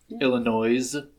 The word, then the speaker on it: Illinois: I believe we pronounce Illinois with the S. You gotta say the S. – You're not allowed to talk on this podcast anymore. – I think the S is silent.